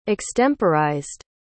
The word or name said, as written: extemporized